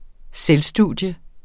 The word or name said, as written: selvstudium